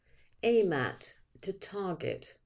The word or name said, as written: aim at